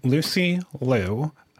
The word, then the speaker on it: Lucy Liyou: The next artist goes under the name Lucy Liu. And yes, it is in part a tribute to the actor Lucy Liu, but this person spells the artist name differently. It's spelled Lucy like Lucy and Liu like L-I-Y-O-U.